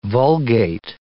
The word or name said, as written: vulgate